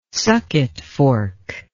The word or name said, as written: sucketfork